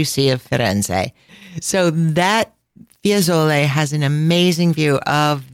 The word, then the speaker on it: Fiesole: Another zona, another neighborhood of Firenze is called Fiesole. And they kind of blur that pronunciation together, Fiesole. It's spelled F-I-E-S-O-L-E.